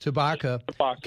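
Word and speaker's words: Tshibaka: So I understand Kelly Tabishka, if I mispronounced that. Tabaka. – Tabaka.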